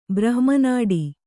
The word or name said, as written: ಬ್ರಹ್ಮನಾಡಿ